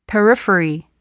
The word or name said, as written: periphery